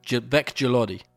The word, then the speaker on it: Veck-Gilodi: It's basically how you said it, Jamie. Veck-Gilodi. Stupid name, right? Actually, I don't even know if that's how you pronounce it.